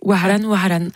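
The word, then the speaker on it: Wahran: Waran, je prononce mal. Vas-y. – Tu étais vraiment très proche. Waran, qui veut dire Oran.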